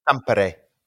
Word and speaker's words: Tampere: I thought it was really interesting how you pronounced that place name. Can you pronounce it again? – Tampere. – Right, because it's you didn't go Ampere. You put an accent on that.